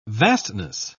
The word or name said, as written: vastness